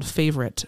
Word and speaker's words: Favoryt: Yeah, I want to mention one of my favorite shop locals lately is um, a family business called Favoryt, Favoryt Brand. Um, it's spelled F A V O R Y T, Josh and Amy Woods.